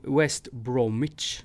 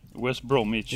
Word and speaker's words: West Bromwich: West Bromwich. – Bromwich. – West Bromwich.